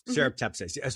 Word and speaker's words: Serrapeptase: How do you, when you choose your product line, how do you know, like you said, your first one, the Serrapeptase, how do you say that? – Serrapeptase.